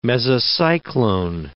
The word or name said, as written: mesocyclone